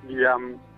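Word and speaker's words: Leham: Liam.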